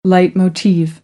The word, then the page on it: leitmotiv: American English; oʊ